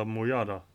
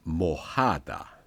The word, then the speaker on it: mojada: El agua está mojada. – Mojada. – Mm. – Varför står det J och inte H då? – Det är en av de underbara saker som spanskan har. J uttalas H.